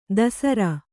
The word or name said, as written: ಧಸರಿ